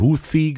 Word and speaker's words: Houthi: Also du hast jetzt einmal Houthi gesagt. Das ist, glaube ich, die korrekte Aussprache. Sprachweise, ne? Also mit dem englischen TH eigentlich heißen die Houthi-Rebellen. Ne?